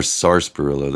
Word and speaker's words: sarsaparilla: Sioux City sarsaparilla. Right. Or sarsaparilla. There's an R there and I never pronounce it, but whatever.